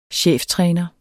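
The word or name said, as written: cheftræner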